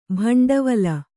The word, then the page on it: ಭಿಂಡಿವಾಲ: Kannada